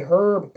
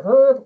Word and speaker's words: herb: Don't pronounce the H. We Herb.